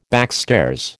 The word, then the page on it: backstairs: American English